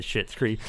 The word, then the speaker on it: Schitt's Creek: And for those that don't know, that's pronounced, or it's not spelled. It's not pronounced. It's spelled. It's spelled, pronounced the same way as what I just said. Schitt's Creek. It's spelled SCHITT'S.